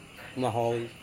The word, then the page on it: imahoi: Serewen